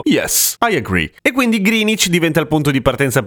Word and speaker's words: Greenwich: che si scrive Greenwich ma si legge Greenwich,